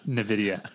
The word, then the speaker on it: NVIDIA: And I was sitting there, I was reading that, and it reminds me of NVIDIA, or how do you say it? – NVIDIA. – NVIDIA or NVIDIA.